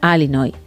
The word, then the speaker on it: Illinois: Dice que pronuncia Alinoy.